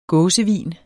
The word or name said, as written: gåsevin